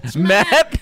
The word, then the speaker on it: Mac: You didn't even say his name right. – Yeah. I'm just. I have to. – Oh, actually, it is Mac, isn't it? – It's Mac. – Mac. – I thought it was Matt.